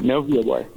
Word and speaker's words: Novio Boy: And now I know the first production of the season was a touring production of award-winning Gary Soto's, is it Novio? Novio Boy? Am I pronouncing that correctly? – Yeah, that's so, right, Novio Boy.